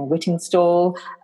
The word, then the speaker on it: Whittingstall: It's really nice that we've got Nigella, uh, Hugh Fernley Whittens, I can never say his name, Whittingstall, um, the one who swears, Gordon Ramsay.